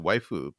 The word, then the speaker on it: waifu: It's a romanization of the, you know, English word wife, and it's waifu because they don't have just an F sound as a separate syllable.